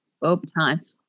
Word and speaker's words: Beauxbatons: We also get to meet uh, Madame Maxime, the headmistress of Batons out in France. – Beauxbatons. Wow, yes. It's French.